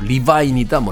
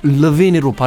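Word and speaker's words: Levine: 마룬 5의 보컬 아담 러빈, 네, 이 성에 대해서는 이야기가 많았어요. 뭐 리바인이다, 뭐 레빈이다라고 했는데 미국의 한 토크쇼에 나와서 아담 르빈이 자신의 이름은 르빈으로 발음하는 게 맞다라고 이야기를 하게 됐습니다.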